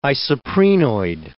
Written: isoprenoid

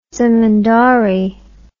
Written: zemindari

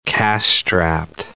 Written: cash-strapped

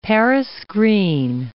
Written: Paris green

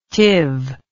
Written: Tiv

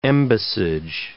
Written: embassage

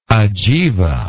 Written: ajiva

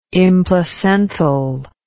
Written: implacental

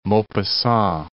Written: Maupassant

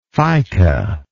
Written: fica

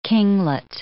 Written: kinglet

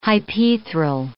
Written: hypaethral

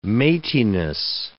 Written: mateyness

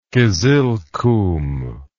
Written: kizilkum

